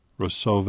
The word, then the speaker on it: Roseau: Now, tell me how to pronounce, since I've not been to this part of the Caribbean, is it how to pronounce R-O-S-E-A-U, the valley where the Valley Church is? – Roseau.